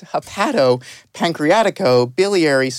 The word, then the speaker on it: hepatopancreaticobiliary: So the first question I want to start out with is, what is hepatopancreaticobiliary surgery? Did I even say that right? – You said it well, and it's exactly what it says.